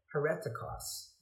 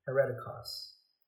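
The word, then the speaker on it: hereticos (αἱρετικός): It's translated from a Greek word where it comes from the word heretic, and it sounds actually more like hereticos. That's how that word is pronounced hereticos.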